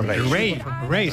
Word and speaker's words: Raye: Ray, se escribe Raye.